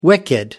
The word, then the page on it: wicked: American English